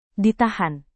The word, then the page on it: ditahan: Indonesian